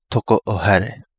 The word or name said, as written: Togo O Hale